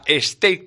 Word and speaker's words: steak: Espero que para todos los públicos habrá steak tartar. Se pronuncia así, Yo mira que el inglés se me da mal, pero no es stick, como dicen la mayoría, que stick es un palo.